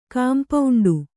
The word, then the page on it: ಕಾಂಪೌಂಡು: kampauṇḍu